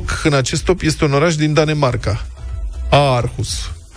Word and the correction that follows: Aarhus: Orhus. – Mă scuzați. Orhus scris cu a dublă.